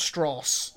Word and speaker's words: Strauss: It's pronounced Strauss.